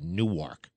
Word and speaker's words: Newark: It's called Newark, Delaware. It's spelled Newark, but they call it Newark.